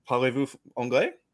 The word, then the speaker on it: Parlez-vous anglais: Maybe I've been saying it wrong. Parlez-vous anglais? – Parlez-vous anglais? Yeah, good. Yeah, that's the one.